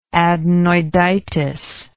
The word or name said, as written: adenoiditis